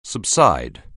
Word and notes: subside: anglais

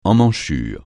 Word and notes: emmanchure: francais

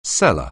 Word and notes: cellar: anglais

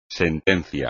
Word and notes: sentencia: espagnol

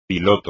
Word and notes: piloto: espagnol

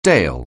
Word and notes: dale: anglais

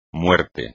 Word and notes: muerte: Spanish

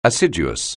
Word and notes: assiduous: anglais

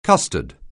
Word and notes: custard: anglais

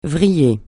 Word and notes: vriller: francais

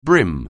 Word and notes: brim: anglais

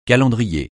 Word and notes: calendrier: francais

